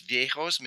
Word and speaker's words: Viejos: Well, looking at another celebration, and forgive me if I mangle the pronunciation here, but the Dance of the Vijos, is there a story behind that? – It's Viejos, means old man or little old men, viejitos.